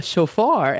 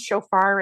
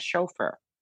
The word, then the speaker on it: shofar: Are you sure it's pronounced shofar and not chauffeur? – I think it's either way, like tomato, tomato. – Right. – Potato, just potato. Making a dumb joke. How do you say it? – Shofar. – Shofar. It's a shofar.